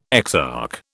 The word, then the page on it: exarch: British English